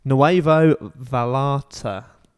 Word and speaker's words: Nuevo Vallarta: Nuevo Vallarta. That's how you pronounce it.